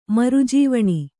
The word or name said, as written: ಮರುಜೇವಣಿ